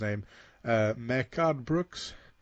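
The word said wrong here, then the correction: Mehcad: Mikad Brooks.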